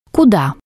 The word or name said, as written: kuda